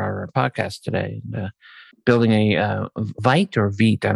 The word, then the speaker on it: Vite: As I understand it from Evan, it's Vite.